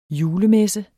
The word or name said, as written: julemesse